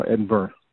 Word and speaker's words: Edinburgh: You know, like Edinburgh is spelled B-U-R-G-H in Scotland, but it's pronounced Burra, you know, Edinburgh.